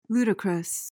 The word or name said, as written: ludicrous